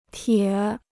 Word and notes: 铁: tiě

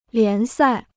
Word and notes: 联赛: lián sài

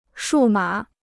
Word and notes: 数码: shù mǎ

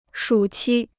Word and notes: 暑期: shǔ qī

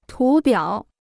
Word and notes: 图表: tú biǎo

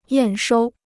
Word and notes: 验收: yàn shōu

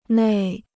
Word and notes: 内: nèi

